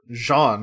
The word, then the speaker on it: Jean: And owner mispronounces Gene as John Mitchell. – John Mitchell. – And he's Jean-Michel Sorel. And I love this thing of like, oh, it's American. It's John Mitchell. Yes. Because, no, it's Gene. – I'm pretty sure Gene, or Jean, as he pronounces it, I'm pretty sure he's supposed to be French or something.